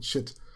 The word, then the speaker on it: Chit: um Chit mit, nur mit CH geschrieben. – Ja, ja, Chit mit CH. Nicht Shit, sondern Chit. Ja, habe ich wohl gerade falsch ausgesprochen.